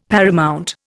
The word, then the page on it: paramount: Indian